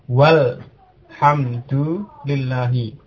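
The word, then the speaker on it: walhamdulillahi (والحمد لله): Tapi kalau di tengah kalimat atau ada kata lain yang mendahului, misalnya ada wawu ya, maka alifnya tidak diucapkan. Dibaca walhamdulillahi.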